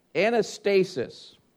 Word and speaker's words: anastasis: the word for resurrection is pronounced anastasis.